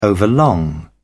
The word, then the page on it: overlong: British English